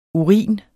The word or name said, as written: urin